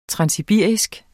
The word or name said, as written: transsibirisk